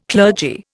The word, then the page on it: clergy: Indian